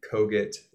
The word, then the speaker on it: Kogut: You can also reach me there or I'm on all the socials at Ben Kogut. Last name is spelled K-O-G-U-T.